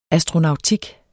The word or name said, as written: ASTRONAUTIK